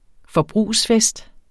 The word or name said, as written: forbrugsfest